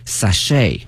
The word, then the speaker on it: sachet: 那么这个词啊它的发音哈，同学们要注意，首先呢我们看一下 ch，它呢是发成 sh 音哈，不是 ch 音哈，同学们注意。另外一个呢，这个 t 是不发音的，t 是不发音的，所以呢我们要注意这个词。另外呢，在美式发音当中，它的重音放到后面，sachet 啊，sachets。